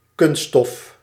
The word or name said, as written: plastic